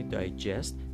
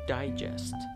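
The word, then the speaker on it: digest: اگر شما این استرس رو بیارید روی سیلاب یک معنی هم عوض میشه یعنی میشه دایجست دایجست حالا اینجا استرس و اینو دیگه نمیتونیم بگیم دیجست فقط یک تلفظ داره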